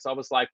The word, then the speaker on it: Szoboszlai: Szoboszlai play I didn't even know how to pronounce his name before we signed him I was really mm-hmm.